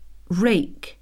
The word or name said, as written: rake